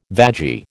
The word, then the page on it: vagi: American English